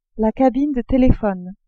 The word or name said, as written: cabine de telephone